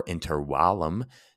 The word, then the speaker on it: intervallum: Etymologically, interval comes from the Latin intervallum or intervallum, with the correct pronunciation in Latin, which refers to space between ramparts.